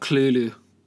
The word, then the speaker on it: Cthulhu: He's talking about Cthulhu. That's how you say it in the alien tongue. Cthulhu. That's how H.P. Lovecraft said it's pronounced. – That sounds like... – Because it's an alien word pronounced in an alien tongue. – I just read, it says, make sure the second syllable is made with a guttural noise.